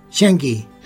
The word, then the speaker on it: Zheng He: Y entonces todo el mundo los odiaba, y este dijo, ojito, no me lo toque, y de hecho eligió a un musulmán, Zheng He. ¿Cómo se pronuncia? – Zheng He.